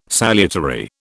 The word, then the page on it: salutary: British English